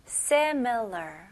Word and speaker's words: similar: se, me, 그 다음에 l 발음. Lur. 네, 끝까지 R 발음을 힘을 주시면서 마무리하셔야 돼요. Similar. 오케이? 강세는 앞부분이에요. Similar.